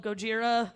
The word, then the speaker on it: Gojira: I enjoyed watching the old Japanese one, which is called Gojira. It's not an accent thing. It's spelled G-O-J-I-R-A.